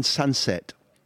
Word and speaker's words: Sancet: called Iron Sunset, and that's pronounced the best I can manage. So for anybody who doesn't know about this kid, Sunset is spelled S-A-N-C-E-T, and Ayan is a Basque name.